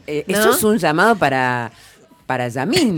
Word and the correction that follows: Lamine: ¿Lo dije bien? – Sí. – No. Lamine.